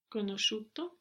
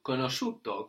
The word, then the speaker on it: conosciuto: Conosciuto. – Conosciuto. Good. And this is spelled C-O-N-O-S-C-I-U-T-O. And the I there isn't pronounced. It's just showing us that the S-C gives us a sh sound.